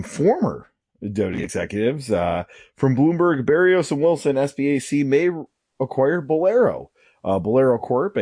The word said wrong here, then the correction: SPAC: a operator of bowling centers at SPAC is the proper pronunciation.